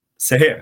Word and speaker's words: Seher: Kajsa Saja? Saja? Uttalar man hennes Seher. Seher, så är det.